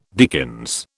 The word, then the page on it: dickens: British English